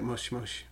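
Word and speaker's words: moshi moshi: Nope, he's just reminding us in a mushy, mushy. – Gentle way. Is that how you say it? It's more like mosh, mosh.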